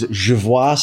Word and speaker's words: Jervois: Not to be mistaken with Jervois Street. Yep. It is Jervois Street. You have a very nice little accent on the OIS there.